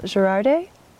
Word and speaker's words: Girardet: I'm here with Phil Girardet.